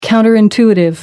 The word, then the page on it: counter intuitive: American English